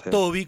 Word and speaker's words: Tobey: Yo te preguntaba en previo si se pronunciaba Toby, como uno cree en la pronunciación anglosajona, porque se escribe Tobey, ¿verdad? – Sí.